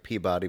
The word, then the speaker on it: Peabody: Wait, are we pronouncing it not like Peabody, but Peabody? It's Peabody. – Peabody. – That's how you pronounce it in Massachusetts.